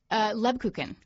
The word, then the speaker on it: Lebkuchen: Lebkuchen. – Lebkuchen.